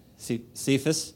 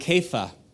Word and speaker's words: Kepha: But it's you actually pronounce it Kepha. Kepha. Okay, true story, look it up. I even watched The Passion of the Christ, and when he refers to Peter, he calls him Kepha.